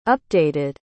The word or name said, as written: updated